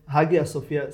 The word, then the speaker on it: Hagia Sofia: Alebo Aja Sofia, nie sa to má asi vyslovovať. – V grešne Hagia Sofia. – Aja Takže Sof... je to v pohode, môžem povedať Hagia Sofia. Aja Sofia je skôr turečtina.